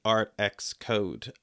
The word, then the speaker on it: ArtXCode: So I'd love to now talk a little bit about ArtX Code.